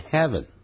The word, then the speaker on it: heaven: 而天堂倒是念成 heaven, H-E-A-V-E-N。这个念成 haven, 啊就是避难所。